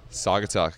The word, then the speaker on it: Saugatuck: We're getting ready for a fun evening with Saugatuck. Did I pronounce that right? – Saugatuck, got it right.